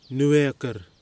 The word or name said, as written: Nuwekur